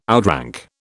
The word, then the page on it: outrank: British English